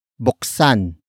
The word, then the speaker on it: buksan: Word for word it's buksan ang iyong puso. Buksan ang iyong puso. Buksan ang iyong puso. The first word is buksan. Buksan. Buksan. Buksan is spelled as B U K S A N.